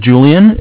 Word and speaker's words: Julian: My name is Julian. It's J-U-L-I-A-N.